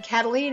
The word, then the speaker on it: Kataline: Karen is spelled the normal way, no goofy Y's or Q's, K-A-R-E-N, and Kataline is spelled K-A-T-A-L-I-N-E.